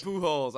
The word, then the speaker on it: Pujols: Pujols? Oh, dear God. – Why? What's wrong with him? – Pujols? – Yeah. – No, your pronunciation of it. – Oh, okay. – He's like one of the best players of all time. Holy So how do you say it? – Pujols. – Pujols, all right.